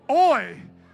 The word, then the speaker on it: Hoy: Hoy. It's H-O-Y, but you don't pronounce the H, right?